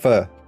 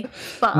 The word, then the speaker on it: pho: Not really. Pho. – Not? Oh well. It's I tried. Pho. Close enough. – Yeah, you're gonna go down and you're gonna go up.